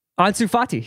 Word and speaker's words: Ansu Fati: Ansu Fati.